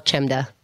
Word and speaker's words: Chemda: Keith and the Girl or my name's Chemda. It's spelled Chemda. – C-H-E-M.